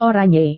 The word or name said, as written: oranye